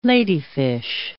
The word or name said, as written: ladyfish